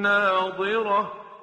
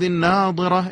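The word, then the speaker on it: ناضرة: وجوه یومئذ ناظره الى ربها ناظره، دقت بکنید تلفظ ضاد ناظره که در آیه 22 هست با ناظره که در آیه 23 هست خیلی تفاوت داره.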